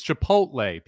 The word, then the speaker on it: chipotle: No, because some people say chipotle. – Yeah. – They put like the L before the T. – I think chipotle was actually even closer than the actual pronunciation of it. It's chipotle, people. Chipotle.